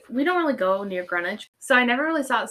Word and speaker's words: Greenwich: Yes, because it happened in Greenwich. Which, by the way, if you ever look up this case, it's spelled Greenwich, but no one calls it Greenwich here.